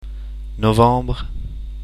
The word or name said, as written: novembre